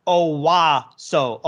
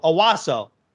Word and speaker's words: Onwuasor: That's the That's the proper way to pronounce his name. Patrick Onwuasor. Onwuasor. It's Patrick Onwuasor.